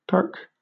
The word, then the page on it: perk: British English